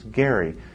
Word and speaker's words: Gerry: and now we call it a gerrymander because we can't remember that his name was actually pronounced Gerry.